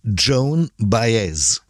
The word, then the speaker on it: Joan Baez: Dann haben wir noch die Sängerin Joanne Baez. Wie wird die eigentlich richtig ausgesprochen? Joanne Baez? – Baez? Nee, es ist tatsächlich Joan Baez. – Joan Baez, vielen Dank.